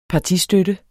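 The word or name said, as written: partistøtte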